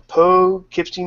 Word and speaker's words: Poughkeepsie: So moving on, that voice you heard is the man from Poughkeepsie, New York. See, I say it right, not unlike Sorg, who says like poo and...